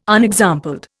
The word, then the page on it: unexampled: Indian